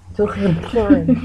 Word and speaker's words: Turin: It's a hard R. – Yeah. – Turin. – Yeah. It's a guttural R. – Turin. – That's how it's technically pronounced, but.